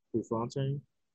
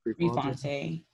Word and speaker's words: Prefontaine: Prefontaine. – Per- Prefontaine. – Prefontaine. Like Ricky Fontaine.